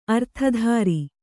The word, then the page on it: ಅರ್ಥಧಾರಿ: Kannada